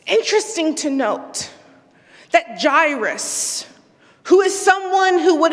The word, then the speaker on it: Jairus: You can call him Jairus, J-Iris. There's many ways we've heard this pronounced. I'll call him Jairus.